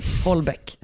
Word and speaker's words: Houellebecq: Vi läste Michel Houellebecq. Mm. Som han tydligen uttalas. – Mm. – Holbeck.